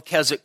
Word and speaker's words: Keswick: Then there was the Keswick Convention, and that's Keswick, don't pronounce the W. And around the 1870s or so, there was a conference called the Keswick Conference in England.